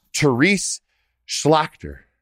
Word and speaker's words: Terese Schlachter: And without further ado, I have retired Colonel Greg Gadson and Terese Schlachter.